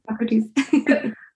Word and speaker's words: Hippocrates: We we go back to to Hippocrates. I think I said that right. Hipp- Hippocrates. Yeah, Hippocrates.